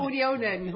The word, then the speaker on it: Unionen: Uh, we will be talking to Swedish unions, Unionen. Have I pronounced that right? – Unionen. Unionen. Sorry, my Swedish is appalling, as you may have gathered.